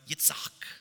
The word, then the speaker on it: Yitzhak: Uh, the name Isaac in Hebrew would be pronounced Yitzhak. Yitzhak.